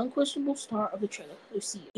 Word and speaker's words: Lucia: It says fans are already in love with Lucia. I think that's how you say her name. L-U-C-I-A, Lucia. – Lucia.